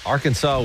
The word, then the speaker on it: Arkansas: The only two states, that end in a silent S. – Oh. – Arkansas won.